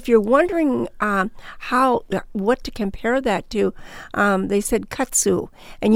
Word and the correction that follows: kudzu: It's katsu, how do you spell that? – K-U-D... Z-U. – Oh, kudzu. – Okay. I probably would say kudzu, but okay.